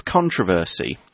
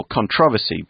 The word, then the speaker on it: controversy: Okay, so the article continues, the referendum campaign was full of controversy, or controversy, both pronunciations are okay, and ended with the Leave voters winning 52% to 48%.